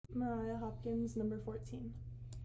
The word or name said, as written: Mariah Hopkins